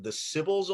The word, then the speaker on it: Sybils: And one of the coolest things I found in your biography, your background, is that you've been active with something called the Sybils Awards. Am I pronouncing that right? Yeah. – You are.